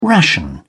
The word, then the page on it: ration: British English